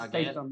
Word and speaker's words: Statham: How do you even say his name? – Statham? – Statham. It's Statham, yeah.